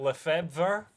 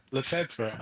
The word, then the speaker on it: Lefebvre: Now, can you pronounce this word? It's spelled Lefebvre. How do you pronounce that? – Lefebvre. – Lefebvre. I'm just going to say Lefeb.